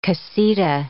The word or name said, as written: casita